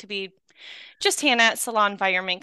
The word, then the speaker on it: Salonvironment: So I'm going to jump into the interview with Salon Environment. For my listeners that don't know, and you know, I keep pronouncing your business incorrectly. I keep saying Salon environment, but it's salon environment, right? – Yes.